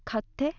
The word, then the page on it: katte: Japanese; frage